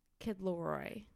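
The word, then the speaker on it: Kid LAROI: When I see the name, it looks like Lothario. I'm just you are dyslexic. Like, it's okay. – How do you say his name again? – Kid Leroy. – Kid Leroy.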